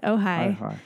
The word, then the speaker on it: Ojai: Thank you for pronouncing that, because I would have totally ruined it. – Oh, yeah, it's Ojai. That is at Greater Goods,